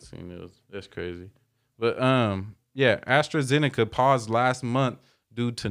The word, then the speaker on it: AstraZeneca: AstraZeneca. – You said that right. – Yeah. Oh, you know the name already? – AstraZeneca, yeah.